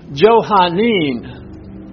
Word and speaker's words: Johannine: Now, this next word is pronounced Johannin. You see that? J-O-H-A-N-N-I-N-E.